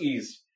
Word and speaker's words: Ys: I actually finished a game recently called the series is spelled YS. – Oh, pronounced Ease.